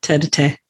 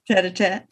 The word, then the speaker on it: tête-à-tête: Yeah, tête-à-tête. – Tête-à-tête.